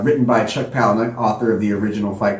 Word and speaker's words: Palahniuk: I was about to ask how you pronounce his last name. I've never heard anyone say it. – Chuck Palahniuk.